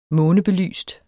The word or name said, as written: månebelyst